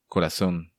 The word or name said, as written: Corazon